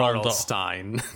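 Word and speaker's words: R.L. Stine: I think before I read Harry Potter, the only books I read was like R.L. Slime and all these kinds of stuff, including R.L. Stein. – R.L. Stein. See, can't even say the name right.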